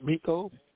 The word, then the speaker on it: Miko: Tim, I'd like to get a hold of the young man, Meek. I don't know why I keep mispronouncing his name, but Miko.